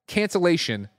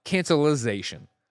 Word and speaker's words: cancellation: Andy's favorite fucking loser wrote in and said, the word is cancellation, not cancelization. Which is 100% true, and that is definitely one of my ticks. You know, like, similar to how somebody's up on the roof. Like, I understand. I'm saying it wrong. I will try to be better about it. You are right. Cancellation is correct.